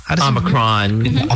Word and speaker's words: Omicron: Omicron? – Omicron.